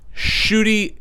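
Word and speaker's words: Ncuti: Shuri Gatwa. – Is that how you say it? – Yes. – Wow. I'm glad to hear you say that because I have been butchering that name. – Shuri. – Shuri. – The NC is an SH in Rwandan.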